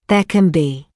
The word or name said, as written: there can be